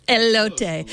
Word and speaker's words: Elote: Do you know if I pronounced that right? Elote. Elote. Thank you. Elote. Elote. Thank you.